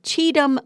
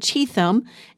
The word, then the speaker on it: Cheatham: Apparently, I said Cheatham. And that was not correct, so I wanted to correct myself. It's Cheatham Memorial Methodist Church. I apologize for saying the name wrong yesterday. There's an H in it. The H is silent.